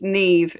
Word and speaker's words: Niamh: And Niamh, did I pronounce her name correct? – Yeah, it's Niamh. It's an Irish name.